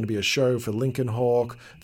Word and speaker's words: Lincoln Hawk: Lincoln Hawk. – Lincoln Hawk.